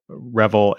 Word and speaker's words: Reville: And so if anybody's curious, though, if they do hop on Twitter, your last name is spelled R-E-V-I-L-L-E. So it's at Paul Revel.